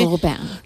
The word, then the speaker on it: Lupin: Lupin.